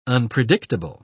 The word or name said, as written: unpredictable